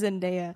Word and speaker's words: Zendaya: It is Zendaya.